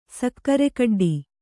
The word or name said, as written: ಸಕ್ಕರೆಕಡ್ಡಿ